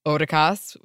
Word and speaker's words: Otakas: Otakas is the best, and Kim put in a pronunciation note, pronounced Otakas with a long O like in show, so thank you.